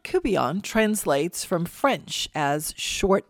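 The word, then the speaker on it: courtbouillon: Looks like court bouillon, but it's pronounced cubillon.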